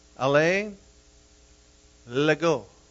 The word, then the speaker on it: Alain Legault: And His first name is spelled A-L-A-I-N, and his last name is spelled L-E-G-A-U-L-T. It's pronounced Alain Legault. L-A like is Los Angeles, L-A, Legault.